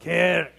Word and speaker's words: kirk: Could you say that with me? The kirk. And I want you to do it again, and I want you to say it like you're clearing your throat. Kirk.